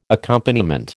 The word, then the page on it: accompaniment: American English